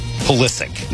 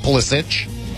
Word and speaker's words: Pulisic: trying to continue that momentum, and their big guy, Christian Pulisic, is going to play. – Good job. – Pulisic. Not Pulisic. – No. – Hard C. Yeah. Looks like it should be Pulisic.